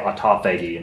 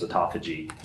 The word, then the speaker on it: autophagy: I used to say autophagy until someone told me it was autophagy.